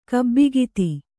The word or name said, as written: ಗುಬ್ಬಿಕದ